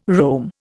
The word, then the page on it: roam: Indian